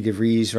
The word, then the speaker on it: DeVries: Uh, I know uh, our good friend Vicky DeVries rhymes with cheese, who we just saw, you know, at the LA shows.